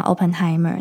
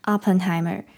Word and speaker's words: Oppenheimer: 那我这边跟大家讲一下，嗯，那时候我讲 Oppenheimer，那发音的部分其实那个 O 应该是要在嘴巴张开一点，它有点像是 opera 的那个啊，所以应该是 Oppenheimer。